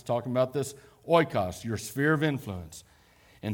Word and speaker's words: oikos: It's pronounced oikos.